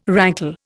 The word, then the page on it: rankle: Indian